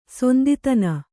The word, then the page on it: ಸಿಂಧುತನ: sindhutana